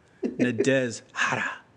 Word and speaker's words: Nadezhda: Nadezhda. – Nadezhda. It's got an H in there. Nadezhda. Nadezhda. Yeah, I don't think you hear the H.